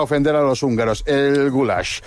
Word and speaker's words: goulash: Es el goulash, es pronunciado así, goulash.